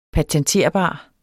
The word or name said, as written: patenterbar